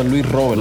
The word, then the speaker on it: Luis Robert: In Cuba siempre se pronuncia Luis Robert.